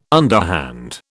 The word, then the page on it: underhand: British English